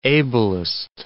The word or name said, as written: ablest